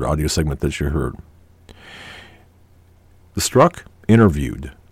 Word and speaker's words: Strzok: And I've pronounced this gentleman's name as Sturzok, but I guess the Z is silent, so it's Peter Strzok. Strzok.